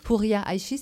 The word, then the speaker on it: Houria Aïchi: Pour vous mettre dans l'atmosphère, eh bien, nous vous proposons une chanson interprétée par Houria Aïchi, c'est comme ça qu'on prononce, Sakina ? – Oui, absolument.